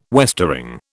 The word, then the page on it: westering: British English